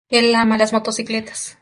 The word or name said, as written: ama